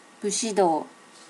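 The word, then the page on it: bushido: native Japanese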